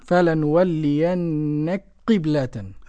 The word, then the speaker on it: فلنولينك قبلة: معانا إدغام الكاف في القاف للسوسي. فلنولينك قبلة. – تنطق قاف مشددة. – آه، مشددة مكسورة. – نعم. – فلنولينك قبلة. ها؟ – فلنولينك قبلة. – أيوة كده، نلغم الكاف في القاف يصيران حرفا واحدا اللي هو القاف المشددة دي، ولا نقول إيه نولك قبلة ونسك كده كما يقول المضللون، واخد بالك؟